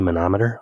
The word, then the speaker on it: manometer: He goes, "Do you mean to say manometer?" I'm like, "Oh, crud, man. Is that how you pronounce it?" He laughs. He goes, "Yeah."